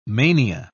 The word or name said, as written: mania